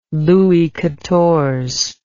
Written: Louis Quatorze

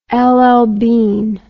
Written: LL Bean

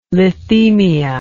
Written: lithemia

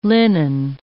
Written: linen

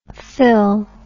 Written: phyl

